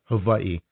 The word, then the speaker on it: Hawaii: Hawaii is just, they're just badass.